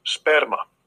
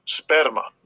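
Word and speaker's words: sperma (σπέρμα): So Strong's G4690, sperma. Sperma. – Which, when you already know what that means. It's that's the pronunciation or the transliteration is sperma.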